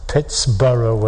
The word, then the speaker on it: Pittsburgh: And you shouldn't be pronouncing this town the German way whatsoever. It should be pronounced the Pittsburgh way.